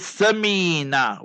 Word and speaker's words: Samina: That is Samina, with a sa, alif, be, te, se, alif, ba, ta, sa. Samina, where your tongue will come out, you must remember there, Samina, you'll see the tongue will come out.